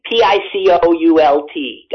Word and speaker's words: Picoult: Jodi Picoult? Is that how it's pronounced? – Yeah. I don't think I've read her either, but yes. – Okay. Picoult, guys.